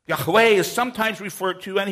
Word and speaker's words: Yahweh: A more correct rendering would be YHWH or YHVH, and pronounced as Yahweh or Yahweh.